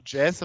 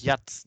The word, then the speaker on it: Jazz: Aber da habe ich ja, weil, weil man sagt ja auch Jazz und man sagt ja auch nicht Jatz.